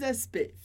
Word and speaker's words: aspect: Mais les aspects physiques, mais os aspectos físicos, as características físicas. Comentário sobre a palavra aspect, que a gente escreve CTS no final, mas a pronúncia é simplesmente aspect. Aspect.